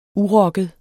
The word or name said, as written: urokket